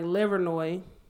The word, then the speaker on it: Livernois: Then I went to Grand River and like Livernois.